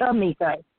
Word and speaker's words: Tamika: Tamika. – I'm sorry? – Tamika from Delaware. – Shanika? Is that it? Am I saying it right? – Tamika. T-A-M-I-K-A.